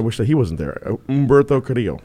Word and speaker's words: Humberto: You don't pronounce the H. We've been through this. – Humberto. – Yeah, just Humberto. – Make believe the H isn't there.